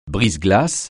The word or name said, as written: brise-glace